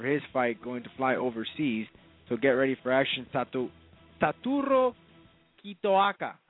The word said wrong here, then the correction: Satoru Kitaoka: I hope I'm pronouncing that correctly, Camilo. – I think it's Satoru Kitaoka.